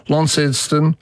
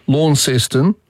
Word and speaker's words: Launceston: As I said, the Tasmanian city of Launceston has Launceston, Launceston, and the original one, Launceston.